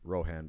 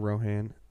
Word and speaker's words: Rohan: And Aldeburgh was the original capital of Rohan before Edoras and the Golden Hall of Meadowseld was built.